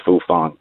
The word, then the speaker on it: Fufeng: Yes, it's, ac- F- it's actually spelled F U F E N G, but it's pronounced Fufang.